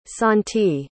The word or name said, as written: sontee